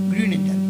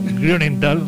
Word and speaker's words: Grünenthal: No sé si lo he pronunciado muy bien. – Grünenthal. – Bueno, Grünenthal, así.